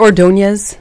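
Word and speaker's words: Ordóñez: Do you pronounce it Ordonez? – Yes, you do.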